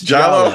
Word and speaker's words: giallo: And I also need help with this genre pronunciation. – I think you got it right. I think you said it right before. – Giallo? – It's Giallo.